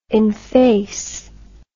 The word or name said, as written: enfacement